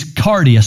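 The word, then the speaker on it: kardia: Now, as we move on into the New Testament, the word heart from the Greek is cardia, spelled with a K, K-A-R-D-I-A. English words that would sound familiar to that would be cardiac.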